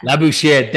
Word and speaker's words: Labouchere: Labouchere.